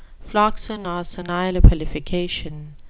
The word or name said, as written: floccinaucinihilipilification